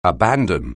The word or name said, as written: abandon